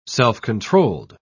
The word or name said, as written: self-controlled